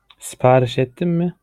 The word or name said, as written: siparis ettin mi